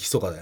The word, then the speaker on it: 密: あれでしょ密と書いてひそかだよね。